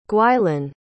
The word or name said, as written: gwylan